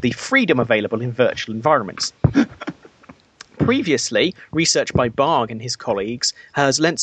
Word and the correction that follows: Bargh: Because if it's spelled B-A-R-G-H, then it's pronounced Barge.